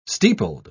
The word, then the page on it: steepled: British English